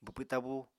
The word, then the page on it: bwpw tabú: Apatani